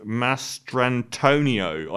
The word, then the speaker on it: Mastrantonio: to Mary Elizabeth Mastriano. Is that her name? I always say her name wrong. Who plays his sister and things. Mary Elizabeth Mastrantonio.